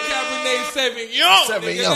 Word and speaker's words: Cabernet Sauvignon: Cabernet Sauvignon. Let's go. – But for my hood niggas in the back, it's a Cabernet Sauvignon.